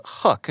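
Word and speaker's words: Huq: And this is from a company called Hook. It's H-U-Q, and I hope I'm pronouncing that correctly. It's sort of a double... – That sounds right.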